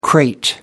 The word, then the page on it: crate: American English